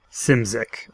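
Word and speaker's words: Szymczyk: But it is Simzik, I believe, is the actual correct pronunciation.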